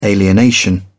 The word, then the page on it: alienation: British English